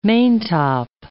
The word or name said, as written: maintop